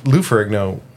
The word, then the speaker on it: Lou Ferrigno: Who Lou Ferrigno is like a Comic-Con staple.